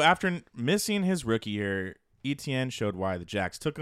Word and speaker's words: Etienne: And that is Travis Etienne will finish as a top 5 fantasy back.